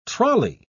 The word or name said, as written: trolley